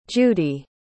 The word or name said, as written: judy